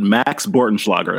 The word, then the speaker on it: Max Bortenschlager: The fact that in the Maryland quarterback, Matt, and Eric, tell me if I get this name right, Max Bortenschlager. Did I say that correctly? – You nailed it. Max Bortenschlager. That's correct.